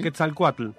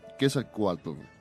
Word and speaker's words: Quetzalcóatl: ¿Se pronuncia así? – Quetzalcóatl. – Quetzalcóatl, que, como chocolate. – TL al final.